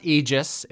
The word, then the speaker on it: Aegis: It's okay, it's pronounced Aegis. It's a Greek word.